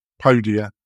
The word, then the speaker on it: Podia: And that's, I always pronounce it incorrectly, Poda. How do you pronounce it? Podia. Yeah. Great people do a lot of online marketing, really good at it as well.